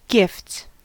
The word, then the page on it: gifts: American English